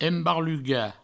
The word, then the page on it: embarlugar: embarluga; Provençal